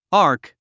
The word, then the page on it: arc: άːrk; アーク